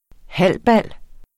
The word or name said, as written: halbal